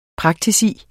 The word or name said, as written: PRAKTIKUS